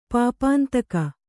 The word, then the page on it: ಪಾಪಾಂತಕ: kannada